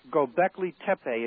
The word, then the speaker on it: Göbekli Tepe: Göbekli Tepe. It's two words in Turkish. Göbekli is spelled G-O-B-E-K-L-I.